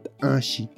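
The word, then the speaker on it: t'axi: Chivo y bueno, pues este se traduce al ñañú del mezquital como tashi, tashi, tashi. Y como es que se escribe, tenemos la consonante T, glotal, A, X, I.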